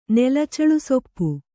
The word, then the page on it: ನೆಲಚೇಳುಸೊಪ್ಪು: nela cēḷu soppu